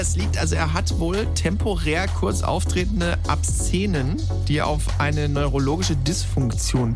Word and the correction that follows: Absence: Wahrscheinlich Absence, wenn er so weg ist. – Absence, ja, wahrscheinlich Absence. Ja.